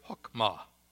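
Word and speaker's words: hokmah (חכמה): We would pronounce the Hebrew word "hokmah."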